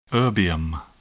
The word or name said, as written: erbium